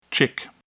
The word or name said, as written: chick